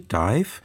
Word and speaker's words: DIIV: Zu Anfang ihrer Karriere schrieben sie sich mal, wie man es englisch ausspricht, also D-I-V-E, Dive. Wegen Verwechslung mit einer belgischen Band gleichen Namens schrieben sie sich dann anders, D-I-I-V, in großen Buchstaben ausgesprochen, aber nach wie vor Dive.